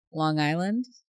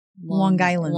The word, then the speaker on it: Long Island: Long Island? – Long Island. – Long Island. Okay. – Yeah, it says Long Island. He was made fun of for pronouncing it with the hard G.